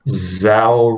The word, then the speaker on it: Zao: My favorite location in steep would definitely be the Zao region in Japan. I don't know if I pronounced it correctly. It's Z-A-O. – Sounds right.